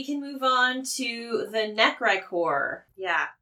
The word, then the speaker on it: Necrichor: I have been given the phonetics on the screen. – Necricor. Yeah, I hear you. – Necricor. – Necricor. – Okay. Necricor. – Necricor. And I will say I have spelled it phonetically every time I've written it.